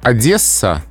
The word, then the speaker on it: Одесса: Брюссель и Марсель произносятся именно так с твердым «с», а вот «Одесса» с мягким.